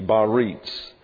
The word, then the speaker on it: Biarritz: That's spelled B-I-A-R-R-I-T-Z, Baritz.